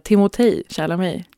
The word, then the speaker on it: Timothée Chalamet: Men från och med nu tycker jag vi alla ska säga Timothée Chalamet.